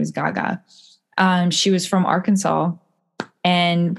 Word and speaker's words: Gege: Her name is Gaga, but it's spelled G-E-G-E.